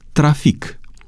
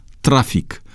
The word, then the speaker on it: trafic: De asemenea, există în limba română unele neologisme mai vechi, cu două variante de pronunțare, ambele acceptate de normă. Așa sunt cuvintele antic, profesor, trafic. Așa se pronunță aceste cuvinte după etimonul francez, dar norma a acceptat și pronunția antic, profesor, trafic, după obișnuința românului de a accentua cuvintele pe penultima silabă.